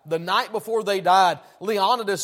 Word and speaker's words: Leonidas: You can go to even further back to Leonidas, or Leonidas, if you want to pronounce that way.